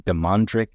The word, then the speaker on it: Damondrick: Again, it's Damondrick Jack, which is spelled D A M O N D R I C K.